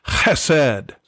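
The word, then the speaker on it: chesed (חסד): This is the expression of a Hebrew word that is pronounced chesed. It's kind of like clearing your throat.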